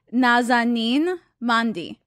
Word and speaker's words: Nazanin Mandi: Nazanin Mandi.